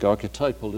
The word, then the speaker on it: archetypal: You probably know the pair of words archetypal and ectypal, or archetypal, as some people like to say, though I think archetypal is the only pronunciation you could defend.